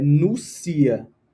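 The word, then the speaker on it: Nucia: Eu queria só fazer uma correção em relação a toda a narração, que a pronúncia do nome Núcia, Núcia no caso, é Núcia. Não tem o um acento no U, é Núcia, nome da pessoa, certo?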